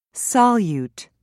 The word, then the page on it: solute: British English